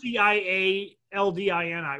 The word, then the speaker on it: Cialdini: It's CIA. LDINI, Robert Cialdini or Cialdini, some people pronounce it, but he has a book called Persuasion.